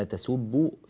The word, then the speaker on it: تسبوا: أيضا ننبه إلى قوله تعالى ولا تسبوا الذين يدعون، لاحظوا في كلمة تسبوا وتنبهوا لشكل الفم عند النطق بهذه الكلمة، ولا تسبوا فيسبوا الله. طبعا السين عندنا سين مضمومة سو، والباء عندنا مشددة بالضم، الحرف المشدد في حقيقته هو بزينة حرفين، والباء هنا مشددة فهي بزينة حرفين، باء ساكنة أولا ثم باء مضمومة بعدها، تسبوا.